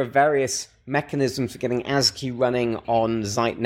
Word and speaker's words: ASGI: We pronounce it ASGI, right? ASGI. – I mean, yeah. Pronouncing ASGI is always difficult. But it's WSGI, so it's ASGI, right? – It's ASGI, right.